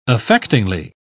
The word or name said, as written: affectingly